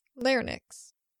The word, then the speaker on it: Larynx: Pronounce the last part like Lynx. It should be like Nyx. Larynx.